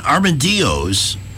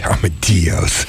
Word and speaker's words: armadillos: And armadillos. – Armadillos. – I'm supposed to pronounce the double L's a Y. – Yeah, that's right.